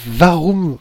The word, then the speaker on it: Warum: Donc euh, les éditions, c'est euh, Varum Vraum, je prononce bien C'est tout à fait ça,